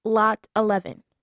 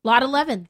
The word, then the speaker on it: Lot XI: It's Lot 11. – Lot 11, so that's even better.